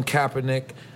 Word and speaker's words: Kaepernick: So Colin Kaepernick, I gotta Did I say it right? – Is yeah.